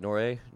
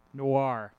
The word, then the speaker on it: noir: Is it Noray? Nora? – Noir. – Noir. There we go.